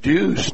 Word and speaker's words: Dues: Chip Otten, 16 years, head man at Coldwater, which has Derek Deuce now as the feature running back, D-U-E-S, pronounced Deuce, not Deuce as you might think, but and Chip made sure he corrected me on that, but Derek Deuce is 70 rushes on the year, 9.4 yards per carry.